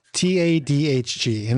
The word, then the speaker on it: Tadhg: His name is Tadj? – Yeah. – It's spelled, because I had the subtitles on, it's spelled Tadhg. Let me do that phonetically. That's Tango Alpha Delta Tango Alpha Delta Hotel Golf. Tadhg.